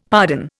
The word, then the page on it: pardon: Indian